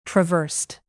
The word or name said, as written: traversed